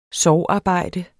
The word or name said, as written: sorgarbejde